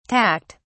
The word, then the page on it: tacked: British English